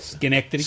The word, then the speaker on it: Schenectady: From Schenectady. Is that how you say it? – Schenectady.